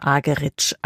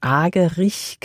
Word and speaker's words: Argerich: Zum Orchester mit Charles Dutoit kommt jetzt Martha Argerich als Solistin dazu. Hierzulande wird sie ja oft eingedeutscht Argerich genannt, aber sie selbst spricht sich Argerich.